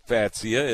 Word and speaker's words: Fatsia: F-A-T-S-I-A. – Fatsia, yeah. You know, yeah. It all depends on, Fatsia is kind of the fun way to say it, isn't it? But it doesn't look Fatsia, it looks beautiful.